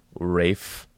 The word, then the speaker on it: Ralph: And everybody went, no, it's pronounced Rafe Fiennes.